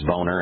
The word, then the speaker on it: Bonner: We've got a congressman named Bonner, but it's phonetically it's Boner, and now we've got Clapper.